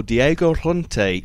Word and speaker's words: Diego Llorente: He put in his weekly piece that Wolves could be in for Diego Llorente from Real Sociedad.